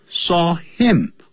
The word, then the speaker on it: him: N-O-S-E 所以特别要记住这些用法打呢都是要用 on 软的时候要用 in、啊、硬的部位要用 on 好 The teacher saw him do it Saw him